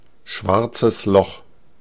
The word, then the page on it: schwarzes Loch: /ˈʃvaʁt͡səs lɔx/